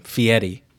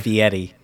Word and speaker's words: Fieri: Yeah, that's how you say his made-up last name, Fieri. – Okay, good to know. – By the way, that is not his real last name, but that is how you pronounce it. That's how he pronounces it, Fieri.